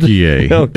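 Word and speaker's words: Türkiye: Turkey-A is the pronunciation the country of Turkey has asked the world to give them.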